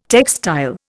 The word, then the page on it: textile: Indian